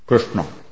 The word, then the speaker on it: Krishna: And not exactly, Krishna is actually, you know, the orthodox pronunciation. Is that the R, the S, and the A are cerebrals, and the N are cerebrals, which you should pronounce with the tongue on the top of your mouth, like Krishna.